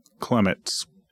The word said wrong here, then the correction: clematis: Clematis. Oh, it's flower. It's flower. – A-T-I-S. Clematis.